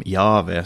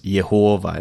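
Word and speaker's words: Jahve: Eh, så är det en omskrivning av det hebreiska det som kallas för tetragra tetragrammet, JHVH, som då är Guds namn, som antag antagligen ska uttalas som Jave, som ibland uttalas Jehova eller på andra sätt.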